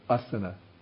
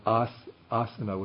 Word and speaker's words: asana: But the actual literal meaning is asana, food, rather than asana with a long A.